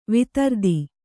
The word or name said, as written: ವೈಧೃತಿ